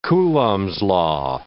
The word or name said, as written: Coulomb's law